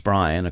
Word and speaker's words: Bryan: Well, the lion represented William Jennings Bryan. Of course, Bryan just coincidentally happens to rhyme with lion.